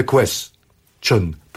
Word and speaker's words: question: To be or not to be, that is the question. Ponieważ tu już jest oszustwo języka angielskiego, że ta ostatnia sylaba, bo on troszkę oszukuje z tym, ale tutaj to się zasadniczo powinno wymawiać question jako jedna sylaba.